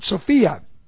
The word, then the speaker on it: Sophia: It's the word Sophia. And it looks like this. And this is S long O P H I A, Sophia.